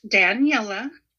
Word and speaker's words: Daniella: Her name is pronounced Daniella.